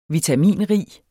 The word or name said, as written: vitaminrig